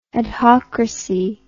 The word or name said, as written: adhocracy